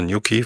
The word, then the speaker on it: New Quay: Auch das wird anders ausgesprochen, als ich dachte. New Quay, vielen Dank nochmal.